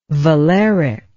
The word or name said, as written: valeric